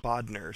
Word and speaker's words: Bodner: Okay, so it's Bodner, so it sounds like an E-R at the end.